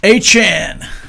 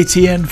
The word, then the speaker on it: Etienne: A-chan. How was he saying it? – A-chan. – Yeah. Apparently we all have been saying it wrong. – But yeah. – Even though they let us say ATN for, what, 6 years? – Yeah.